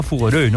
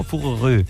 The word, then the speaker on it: Fougeray: Esto se pronunciará Fougeray, ¿no? Fougeray. – Sí.